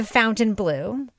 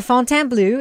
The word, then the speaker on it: Fontainebleau: It's not the Fountain Blue. It's the Fontainebleau. It's like the French version of it.